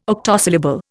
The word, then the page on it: octosyllable: Indian